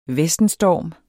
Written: VESTENSTORM

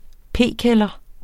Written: p-kælder

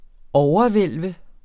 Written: overhvælve

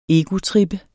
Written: egotrip

